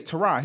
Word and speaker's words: Tyrod: Apparently, we found out Lee Corso mispronounced his name from jump. – So it should be Tyrod. It's Tyrod. His name is Tyrod.